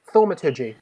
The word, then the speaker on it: thaumaturgy: Can I use, and pardon my pronunciation, thaumaturgy? – Yes, you can use thaumaturgy. How do you say it? – Thaumaturgy.